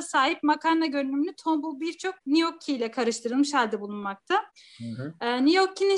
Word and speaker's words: gnocchi: Evet şimdi ikinci yemeğim gnocchi diye okunuyor.